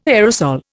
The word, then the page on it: aerosol: Indian